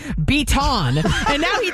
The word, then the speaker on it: Beaton: His last name is Beaton. B-E-A-T-O-N. Okay? Beaton. I know that's how you say it.